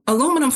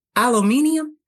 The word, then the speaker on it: aluminum: And sure enough, British say aluminum one way and we say aluminum one way, and we were both right.